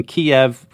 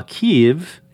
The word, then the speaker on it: Kyiv: So we talked all about the pronunciation of Kyiv, for instance, that pronunciation Kyiv and the spelling K-I-E-V, that reflects an older Russian standard, while Kyiv and the spelling K-Y-I-V do a better job of representing the Ukrainians' own name for their capital city in their own language, Ukrainian.